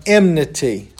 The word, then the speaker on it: enmity: Sin brings man into a position of enmity with God. That word enmity is actually how you say it. I usually get the M and the N mixed up.